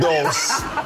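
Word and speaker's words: dulse: How you say it? – Dos. It's like pulse with a D.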